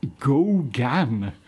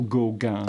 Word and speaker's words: Gauguin: Ooh, Gauguin. Gauguin. They can't spell it any more than I can spell Gauguin. – Oh, on the plus side, you get to pronounce it the right way.